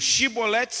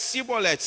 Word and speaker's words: Chibolete: E aqui é a mesma coisa, parecido parecido, Chibolete, Cibolete. Se ele era de Efraim, ele dizia cibolete pois o povo de Efraim não consegue pronunciar essa palavra corretamente.